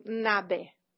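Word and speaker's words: Ngäbe: For example, in Costa Rica, they can be Ngobe, Nobe, Nabe, and we adapted for our film the Panamanian pronunciation of this word. So that's why we call them Nabe.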